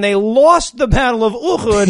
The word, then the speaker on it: Uhud: They're now talking about the Battle of Uhud I think that's that's how it's pronounced. Sure. Uhud.